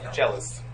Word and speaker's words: cellist: And so this story is about a celloist. On May 28, 1992, the principal... A cellist and the, am I saying that right? Is it cellist? – Cellist.